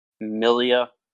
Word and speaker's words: milia: And they offer award-winning treatments for acne, age management, hyperpigmentation, skin tags, milia. Say that right? – Yeah, that's right.